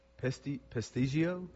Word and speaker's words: Peshtigo: P-E-S-T-I-G-O. – Pestigio? – Pestigio. I said Pestigo, but of course, you really don't need to go by what I say. But it was the exact same day, and it killed 1,200 people. – Good gracious. – I know. – We should do a segment pronouncing words. – P-I-S-T-E-O? – P-E-S-H-T-I-G-O. – Okay, wait a minute. P-E-S. – P-E-S-H-T-I-G-O. – All right, move on.